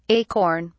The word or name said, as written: acorn